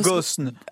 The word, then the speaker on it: Ghosn: Ghosn.